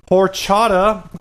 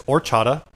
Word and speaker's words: horchata: Horchata. I guess you don't pronounce the H. It's not horchata. It's orchata.